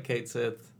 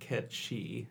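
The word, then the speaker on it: Cait Sith: The way it is spelled is like it's like in Irish or Gaelic folklore or something. It's a creature. But it's pronounced Ketchi because of crazy Gaelic languages and their pronunciations.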